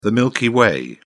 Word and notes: milky way: British English

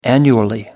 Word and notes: annually: American English

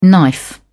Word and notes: knife: British English

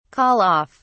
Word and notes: call off: British English

